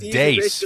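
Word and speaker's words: Deace: Yeah, so Chip Roy, he was on the Steve Deese show, and then a disaster, this guy, Steve Deese. So they're on the show together. And I think it's pronounced Dace for some reason.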